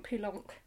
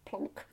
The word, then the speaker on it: Poulenc: Because also, it's written Poulenc. – Poulenc. – But that's not how you pronounce it. – Oh, well. Some might say. – Because then you can also get Poulenc, plonk.